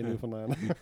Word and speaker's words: Newfoundland: Good pronunciation of Newfoundland, by the way.